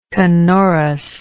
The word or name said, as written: canorousness